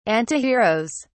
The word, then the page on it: antiheroes: British English